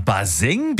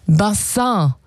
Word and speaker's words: Bassin: Und naja, die Aussprache, die hat ein bisschen gehapert. Also statt Bassin wurde es irgendwann Baseng.